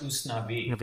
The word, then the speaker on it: Usnavi: There's like U.S. Navy. There's It's pronounced Usnavi, and that's that's a real Cuban thing.